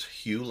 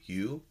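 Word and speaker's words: hu: Now, hue is spelled H-U. It's pronounced hue, like a boy's name, Hugh.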